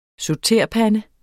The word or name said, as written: SAUTERPANDE